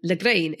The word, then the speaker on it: Lagrein: which is Schiava and Lagrein, if I'm pronouncing correctly.